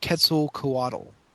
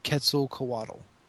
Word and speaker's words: Quetzalcoatl: What I found in my research of pronunciations of it and kind of pretty much what the audiobook pronounces it is Quetzalcoatl. – So they pronounce the O and the A separate? – Yes. Quetzalcoatl.